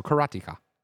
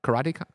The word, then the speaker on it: Karateka: I mean, it's karate and ka. So Karateka. Karateka.